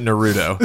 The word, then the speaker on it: Naruto: I pronounce it Naruto.